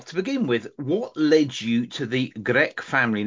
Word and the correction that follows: Gerke: Now, check my pronunciation in German. Is that the correct pronunciation? – No, it's a difficult word. It's Gerke. – Gerke, okay. The Gerke family story.